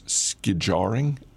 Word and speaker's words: skijoring: It was, the word is skijoring, S-K-I-J-O-R-I-N-G, skijoring, and it's skiing behind horses.